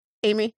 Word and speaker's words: Aimee: Someone asked me once how I pronounce my name. And I'm like, you know, how it's spelled. Aimee.